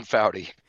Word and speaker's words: Foughty: What's the correct pronunciation of your last name? – It's Tim Foughty. – Foughty. Okay, I apologize for butchering it. It looked like it was spelled Fogarty to me, so I apologize.